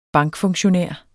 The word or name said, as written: bankfunktionær